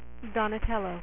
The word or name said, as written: donatello